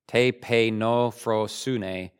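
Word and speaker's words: tapeinophrosyne: Tepeinophrosune. Big word. I had to actually spell it out here phonetically. Tepe, so I'm pronouncing it right. Tepeinophrosune.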